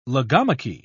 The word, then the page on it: logomachy: British English